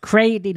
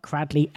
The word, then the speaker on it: Cradley: Can I just point out that it is pronounced Cradley, not Cradley, as per the spelling?